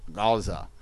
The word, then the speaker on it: Gaza: it was pronounced probably Gaza, because the Ayin, and we have the same thing in Arabic also, and so therefore it is Gaza as opposed to Aza.